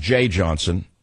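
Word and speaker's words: Jeh Johnson: I know it's pronounced Jay Johnson.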